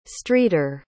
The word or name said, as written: streeter